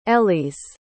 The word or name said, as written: elyes